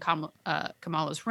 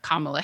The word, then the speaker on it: Kamala: She just appeared in Kamala's room. – I'll watch it. It's pronounced Kamala. I know our vice president, Kamala Harris, is different, but in the show, it's Kamala.